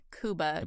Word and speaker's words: Cuba: Cuba Gooding Jr. I believe it's pronounced Cuba.